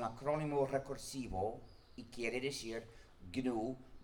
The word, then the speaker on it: GNU: Se escribe GNU y se pronuncia normalmente en inglés GNU, pero en castellano se puede pronunciar GNU como si comenzara por ñ, lo que es el nombre del mismo animal.